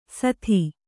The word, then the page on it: ಸಾದಿ: Kannada